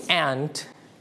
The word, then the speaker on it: aunt: Aunt. – Aunt. – Aunt. Or we can pronounce this word aunt. เราสามารถออกเสียงได้สองแบบคือ aunt และ aunt นั่นเองนะครับ So this word can pronounce in two ways. Okay, you can pronounce aunt or aunt. It's okay. If you pronounce aunt. That means you use American style. But if you pronounce aunt, you use the British style.